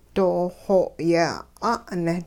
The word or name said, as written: dooxoo yeh 'a'ne'